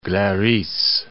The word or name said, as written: Glaris